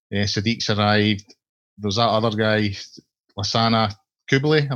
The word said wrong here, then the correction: Coulibaly: Am I pronouncing that right? – Coulibaly is Coulibaly.